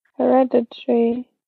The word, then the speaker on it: Hereditary: Da wird das A hinten gar nicht auf, äh, ausgesprochen anscheinend. – Scheinbar, ja. Hereditary.